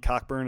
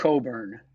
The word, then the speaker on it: Cockburn: The big kid from Illinois, is it Kofi Cockburn? Is that how you say it? – It's Coburn. – Coburn. – Kofi Coburn. – Okay, Kofi Coburn.